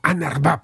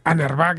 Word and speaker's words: UNRWA: Estas son las siglas en inglés y se pronuncia algo así como UNRWA, por lo que he podido yo ver en los informativos estadounidenses y de la prensa internacional.